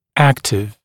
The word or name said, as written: active